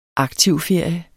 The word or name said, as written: aktiv